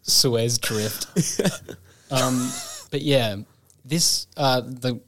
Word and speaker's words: Suez: Suez. – Suez.